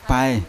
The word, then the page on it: paye: Yawa